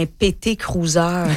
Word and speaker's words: PT Cruiser: Oh, un PT Cruiser. – Prononcé comme ça? – Oui, c'est ça.